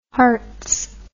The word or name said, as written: Herts